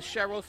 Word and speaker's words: Cheryl: My name is Cheryl, spelled with a C-H-E-R-Y-L, last name Richardson.